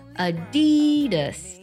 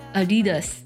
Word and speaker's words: Adidas: 阿迪达斯的它的最标准的读音读作 Adidas 啊，先低再高然后再轻 Adidas。